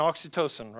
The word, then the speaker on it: oxytocin: I can't pronounce it right. Oxytocin. Is there a nurse that can help me here? oxytocin? Oxytocin, thank you.